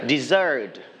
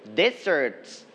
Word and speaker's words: dessert: Dessert. – All right, so dessert, you are going to stress on the second syllable, dessert. One more time, dessert. Correct. But if you are pronouncing the word desert